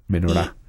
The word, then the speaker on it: menorá: Menorá es eh, como se pronuncia exactamente, es una palabra aguda, digamos, menorá.